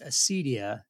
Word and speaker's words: acedia: But it's pronounced Acedia. It's spelled A-C-E-D-I-A.